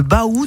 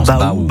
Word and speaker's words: Bau: Là, eh bien, ces grottes portent un nom, le Baou de l'Aubézier. Alors, ça s'écrit B-A-U, mais ça se prononce Baou.